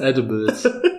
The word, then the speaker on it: Edibles: Edibles. Wie mit einem Ä. Ist die Aussprache.